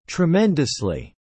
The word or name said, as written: tremendously